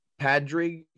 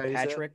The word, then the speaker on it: Padraig: We have Harry Higgs, Paul Casey, Padraig Harrington. – I think it's just pronounced Patrick.